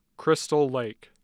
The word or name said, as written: Crystal Lake